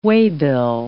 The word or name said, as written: waybill